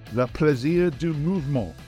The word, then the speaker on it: le plaisir du mouvement: After another fantastic week of European action, lots of controversy, in the opinion of Stuart Barnes, le plaisir du mouvement. Is that correct? Did I say that right, Stuart? – You said it quite beautifully.